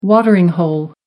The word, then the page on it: watering hole: American English